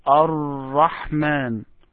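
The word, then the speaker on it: Ar-Rahman (الرحمن): As if you have two Ra. It is not one Ra, but try to pronounce it and make it harder. Ar-Rahman.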